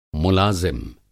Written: मुलाज़िम

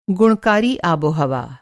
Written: गुणकारी

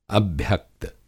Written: अभ्यक्त

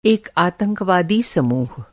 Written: समूह

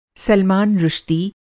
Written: सलमान रुश्दी

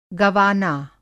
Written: गवाना

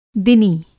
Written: दिनी

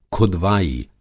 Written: खुदवाई

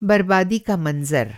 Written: बरबादी

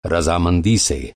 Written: रज़ामंदी

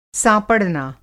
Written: साँपड़ना

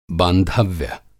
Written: बांधव्य

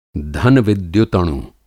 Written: धन-विद्युतणु

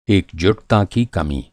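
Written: एकजुटता

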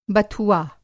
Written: बथुआ

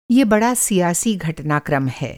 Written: घटनाक्रम

